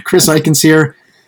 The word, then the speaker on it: Chris Eichenseer: His name's Chris Eichenseer.